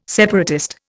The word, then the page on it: separatist: Indian